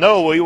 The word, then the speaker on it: Noe: But as the days of Noe, my grandma called him Noe with a long E, and that's the way it is in the Bible and in Matthew.